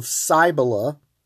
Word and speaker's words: Cybele: But they started the mysteries and the worship of Cybele. If you want to know how to spell that, that's C-Y-B-E-L-E. Cybele, C-Y-B-E-L-E.